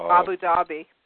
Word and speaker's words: Abu Dhabi: Abu Dhabi.